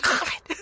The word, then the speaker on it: Khaled: We scratch our KH's. – Yeah, like DJ Khaled, not Khaled. – DJ Khaled. – Yeah, that's what you say his name.